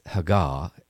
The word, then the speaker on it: hagah: If you look at that word meditate in the original Hebrew, it's Haggah, and we would spell it H-A-G-A-H.